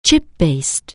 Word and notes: chip-based: anglais

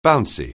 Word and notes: bouncy: anglais

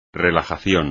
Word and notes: relajación: espagnol

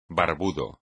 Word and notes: barbudo: espagnol

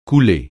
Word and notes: coulée: francais

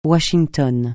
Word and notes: Washington: francais

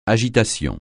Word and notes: agitation: French

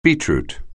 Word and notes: beetroot: anglais

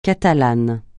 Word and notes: Catalane: francais

